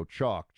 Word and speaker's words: Choq: Chalk. Chalk is spelled with a Q, as in C-H-O-Q.